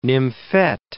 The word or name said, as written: nymphette